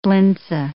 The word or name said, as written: blintze